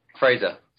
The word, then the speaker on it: Fraser: Fraser. – Fraser. – You know, right? – Americans say Frasier.